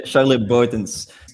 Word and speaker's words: Charles Boutens: It's Charles or Charlie, Charles Boutens.